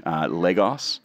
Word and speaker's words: Lagos: San Francisco, New York, Lagos. Lagos? Am I pronouncing it? – Depends who you ask. Lagos.